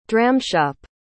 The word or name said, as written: dramshop